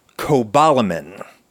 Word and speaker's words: cobalamin: B12 is cobalamin, not cobalamine, as it is often mispronounced by myself and others, but cobalamin, which is the only essential micronutrient based on the toxic heavy metal cobalt.